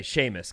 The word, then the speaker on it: Seamus: Is, yes, it's spelled S. It's spelled C. Mus, but okay, Seamus, got it.